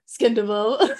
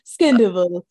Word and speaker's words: Scandoval: And she goes, currently known for Scandoval. – pronounced. – The robot who couldn't pronounce Scandival. And I was like, this is so important to me. It was like, Scandival.